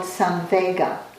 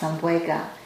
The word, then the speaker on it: samvega: There's a word in Pali, that ancient language that the Buddhist teachings were translated in that describes spiritual urgency as this word called samvega, S-A-M, V as in Victor, E-G-A, sometimes pronounced samvega.